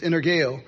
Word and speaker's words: energeo: The word effective in Greek is energeo, or energeo, some people pronounce it, but it's energeo.